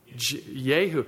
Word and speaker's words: Jehu: Jehu. In fact, Gary pointed out to me last week that in Hebrew, the letter J is pronounced like a Y.